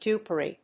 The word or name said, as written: dupery